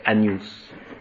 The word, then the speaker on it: Agnus: Le GN en latin, il faut le prononcer comme en français. Agnus.